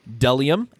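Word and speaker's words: bdellium: Delium and onyx stone. Bonus points for me. I can say delium. Not getting psyched out by the silent B at the beginning of it.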